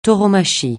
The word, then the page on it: tauromachie: francais